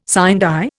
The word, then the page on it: sine die: Indian